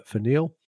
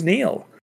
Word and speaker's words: Nhill: So, yeah, that's a couple of factoids and a bit of a situational thing for Nhill. – Now, I just want to ask, because I was really, when you first said Nhill, I was like, that's a bloke's name. Who's Nhill?